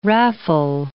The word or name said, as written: raffle